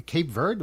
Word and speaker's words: Cape Verde: For instance, Cape Verde, am I pronouncing it right? – Yes.